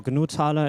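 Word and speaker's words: GNU Taler: Okay, everyone, welcome to our next talk, buying snacks via NFC with GNU Thaler, or GNU Thaler is I think the better pronunciation.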